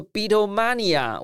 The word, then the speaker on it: Beatlemania: 对啊，就是呃呃，Beatles Beatles，就是说当年从很纯粹的音乐实验跟理念的实践，我相信到最后，Beatles 这这个 Beatlemania，我不知道这样发音对不对？